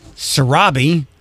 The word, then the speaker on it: Sarabi: Zuzu, Sarabi, Uru.